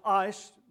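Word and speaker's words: ice (εἰς): Well, the problem is that the word for is translated from a Greek word, E-I-S, getting a little dyslexic in my old age, and it's pronounced ice, most people's vocabulary.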